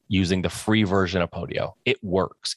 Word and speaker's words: Podio: Use Podio. And that's spelled P-O-D-I-O, Podio.